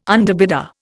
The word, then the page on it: underbidder: Indian